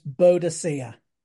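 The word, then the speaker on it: Boudica: Well, when I was at primary school, she was pronounced Boadicea. Now they seem that they want to pronounce her Boadica.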